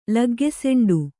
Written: ಲಗ್ಗೆಸೆಂಡು

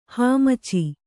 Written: ಹಾಮಚಿ

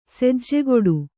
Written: ಸೆಜ್ಜೆಗೊಡು

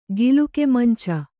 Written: ಗಿಲುಕೆಮಂಚ

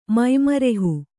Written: ಮೈಮರೆಹು

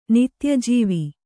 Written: ನಿತ್ಯಜೀವಿ

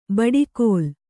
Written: ಬಡಕಲು

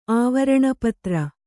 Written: ಆವರಣಪತ್ರ

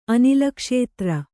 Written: ಅನಿಲಕ್ಷೇತ್ರ

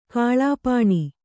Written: ಕಲ್ಪನೆ

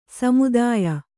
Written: ಸಮುದಾಯ

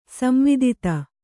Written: ಸಂವಿದಿತ

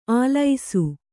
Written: ಆಲೈಸು

